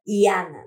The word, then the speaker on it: Dhyana: En todas las redes sociales, estoy en Instagram, en YouTube, estoy en TikTok, en Facebook, soy arroba astro D-H-Y-A-N-A. Es y Ana es una palabra en sánscrito que significa meditación trascendental. Ahí cuando me gradué de, este, de yoga, pues me gustó mucho, conecté mucho con esa palabra y se escribe pues muy parecido a mi nombre que es Diana. Entonces por eso soy Astro y Ana, ¿no?